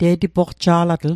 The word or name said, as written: te'tipoqja'latl